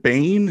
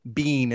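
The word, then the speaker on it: Biehn: I'm gonna give it to you because it's B-I-E-H-N. It's Michael Bean is how he pronounces it.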